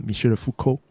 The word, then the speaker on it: 米歇尔·福柯: 后来他就跟我讲说：“ 其实你这个地址就是法国的哲学家米歇尔· 福柯，福柯这个哲学家他的地址。” – 米歇尔· 福柯。 – 是的，你发你发音的很标准，我觉得你有发。